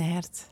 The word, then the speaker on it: Hert: Het groene hart in het Fries, hoe, eh, hoe, spreek je dat uit? – Het groene Hert.